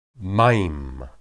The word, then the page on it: mayim: hebrew